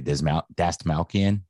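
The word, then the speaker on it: Dastmalchian: I can't say it. Dasmalchian. – Is that how you say it? Yeah.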